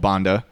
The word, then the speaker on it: Banda: So one particularly awful example of the VOC's operations was The one on the archipelago called Banda.